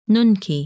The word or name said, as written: Nunki